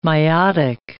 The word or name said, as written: miotic